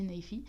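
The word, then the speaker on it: Naifeh: Et donc c'est une série de romans graphiques qui a été écrite et dessinée par l'auteur Ted Naifee Donc ça s'écrit Naifé mais ça se dit Naifee et d'ailleurs je suis euh,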